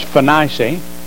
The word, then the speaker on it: Phoenicia: And being brought on their way by the church, that'd be the church at Antioch, they passed through, Alexander Scorby says, Phoenicia. He calls my wife Bernice. I don't know if y'all ever listen to him, but he says that's the correct pronunciation.